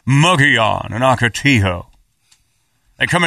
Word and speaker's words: Ocotillo: If all those four-wheel drive ones got found this year, which has blown me away, that they're gone before Ocotillo and Wrecker. Ocotillo, I'm sorry. I'm saying that wrong. Ocotillo and Wrecker.